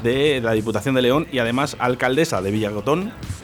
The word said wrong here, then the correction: Villagatón: Villagatón, lo he dicho bien, Villagatón.